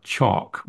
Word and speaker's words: chalk: You're not just talking about chalk, where the L is traditionally silent.